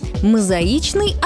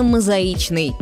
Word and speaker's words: мозаичный: Мозаичный – одно из них. Да-да, именно мозаичный, не мозаичный.